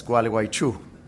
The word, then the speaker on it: Gualeguaychú: but also Gualeguaychú side there in Argentina. The city is, it's good for wintertime illnesses. If you sneeze or have allergies, you can pronounce it well. It's Gualeguaychú.